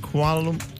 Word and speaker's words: Kuala Lumpur: Is it, Sarah, is it Kuala Lumpur? – Yeah, you said it right.